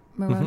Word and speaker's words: Moroni: Is that how you say it? Moroni? – Mm-hmm.